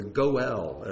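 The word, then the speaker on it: Goel (גֹּאֵל): the Goel, as it's pronounced in Hebrew.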